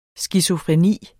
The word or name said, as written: SKIZOFRENI